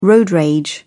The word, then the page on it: road rage: British English